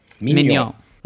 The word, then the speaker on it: Mignon: Mignon. Like filet mignon? – It is spelt like filet mignon, M-I-G-N-O-N, yes.